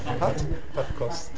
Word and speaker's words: Padkos: So this is his Padkos." Is that correct pronunciation? – Padkos. – Padkos. Excuse me. Padkos.